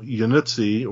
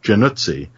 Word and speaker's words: Januzzi: The one they call Yanuzzi or Yanuzzi is supposed to be Januzzi, but nobody there knew how to pronounce Italian names, and they kept they kept saying it Januzzi, and they thought it sounded too much like jacuzzi. They would always laugh, and if anybody had bothered to ask me, I'd have said it's pronounced Januzzi.